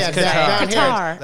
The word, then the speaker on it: Qatar: Qatar, like a guitar. Yeah, Qatar. Yeah. Right. That's exactly it. But that's not how you really pronounce it.